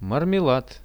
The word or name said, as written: marmelad